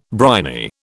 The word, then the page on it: briny: British English